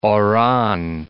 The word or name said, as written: Oran